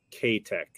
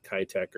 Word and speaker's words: K-Tech: I was listening to maybe somebody on Gabe's stream who was from Japan, and he said it was K-Tech, I think, or something like what Matthew is saying, not Kitech or Keytech, K-Tech.